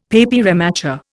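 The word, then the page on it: papier mâché: Indian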